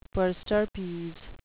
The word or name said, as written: per stirpes